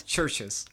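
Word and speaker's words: Chvrches: Okay, and again, how do you pronounce the artist? – I think it's Churches. – Churches. – Because like the V is supposed to be pronounced as a U.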